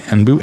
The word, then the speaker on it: Anbu: Anbu, A-N-B-U-U, Anbu, and Blue Wednesday.